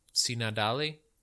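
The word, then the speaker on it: Tsinandali: Sinadali.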